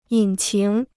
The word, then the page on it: 引擎: yǐn qíng